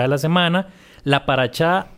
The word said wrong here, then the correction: parashá: Vamos a tratar la Parachá, parashá, Saf. Hoy ando, pero increíble con la S y la, y la C, ¿verdad?